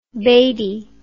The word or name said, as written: batey